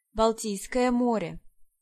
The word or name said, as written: Baltic